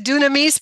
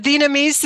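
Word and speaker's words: dinamis (δύναμις): So it animates and gives us, you said dunamis, but dinamis in the modern pronunciation would be dinamis, that we have the power of God to live our life.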